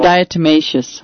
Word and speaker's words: diatomaceous: You can get a product called bentonite, and then also DE, diatomologist earth, I believe I may have said it wrong. – Diatomaceous. – There you go, diatomaceous. I always say it wrong every time.